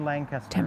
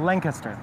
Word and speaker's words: Lancaster: A group of traditional Christian church fellowships, about 330,000 Amish in the United States, 33,000 of which are in Lancaster. – 10%, quick math. – I just said it wrong. In Lancaster.